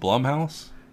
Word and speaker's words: Blumhouse: Is it Blumhouse? – Yes. You used to call it Blumhouse for no reason.